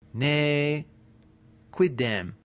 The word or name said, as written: nequidem